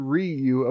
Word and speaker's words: Ryu: actually pronounced close to Ryu. Okay, but as one syllable.